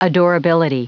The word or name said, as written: adorability